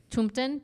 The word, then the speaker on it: tomten: Tomten, T-O-M-T-E-N.